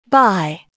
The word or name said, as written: buy